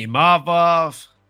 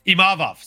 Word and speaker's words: Imavov: It was the Imavov show. Imavov, sorry. Not Imavov. I'm trying very hard not to say Imavov because that's not the way you say his name. It's Imavov.